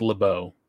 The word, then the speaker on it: LeBeau: For the people at home, it's LeBeau for the people at home, but okay. mm, it's not how the French pronounce it, but all right, go ahead. – That's true, but I think I think X Men, I think the cartoon show pronounced it that way too, so I feel pretty good about that.